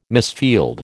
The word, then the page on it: misfield: American English